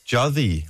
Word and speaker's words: Jytte: Det er dansk, og hun hedder Jytte, men hun kalder sig selv... – Jytte. – Jodhi. – Jodhi, tror jeg, det er sådan, at hun påstår, at hendes navn udtales.